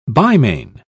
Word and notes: bimane: British English

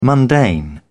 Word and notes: mundane: British English

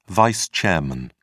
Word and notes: vice chairman: British English

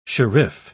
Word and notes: sherif: British English

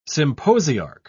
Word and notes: symposiarch: British English

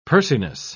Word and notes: pursiness: British English

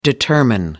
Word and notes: determine: American English

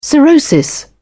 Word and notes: cirrhosis: British English

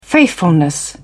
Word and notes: faithfulness: British English

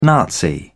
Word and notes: nazi: British English

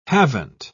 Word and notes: haven't: British English

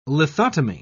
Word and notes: lithotomy: British English

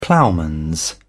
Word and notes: ploughman: British English

